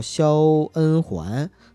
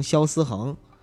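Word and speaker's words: 肖恩桓: 然后他本来呢名字叫肖恩环，但我们呢就非要给他故意读成肖思恒，就是那个环是木字旁的嘛，然后我们就读成恒心的恒，恩嘛，恩情的恩，我们就读成思。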